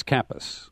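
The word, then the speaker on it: Kappes: I mean, he's got Stephen Capps. I believe that's how you pronounce his. – I think it's Kappas. – Kappas. Okay.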